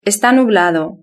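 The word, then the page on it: esta nublado: Spanish (Spain)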